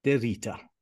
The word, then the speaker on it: de Rita: Today, my guest, please welcome Gabe de Rita.